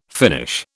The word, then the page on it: Finnish: British English